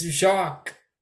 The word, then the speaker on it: Jacques: You don't say the S. You don't pronounce the S. It's Jock.